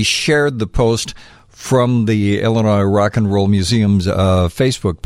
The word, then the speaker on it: Illinois: And that's like the pet peeve of, the S is silent. No, but we're talking noise. – And by the way, we're in Joliet, Illinois.